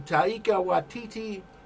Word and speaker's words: Taika Waititi: Is cast now in the Taika Waititi pirate comedy. – Bravo on the pronunciation. Nailed it.